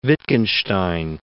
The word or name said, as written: Wittgenstein